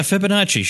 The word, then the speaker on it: Fibonacci: And, you know, talked about things like the Fibonacci number. Am I saying that right? I don't know if I'm saying that right. – Oh yeah. Fibonacci.